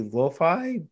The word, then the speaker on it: lo-fi: I think I'm just, it is pronounced usually lo-fi, but I think lo-fi.